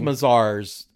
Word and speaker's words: Mazars: Is Mazars how you say it? I thought it was Mazars. M-A-Z-A-R-S. – Mazars. Sorry. So Mazars,